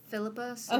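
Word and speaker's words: Phillipa Soo: I don't know how to pronounce her name. Is it Philippa Sue? – Okay.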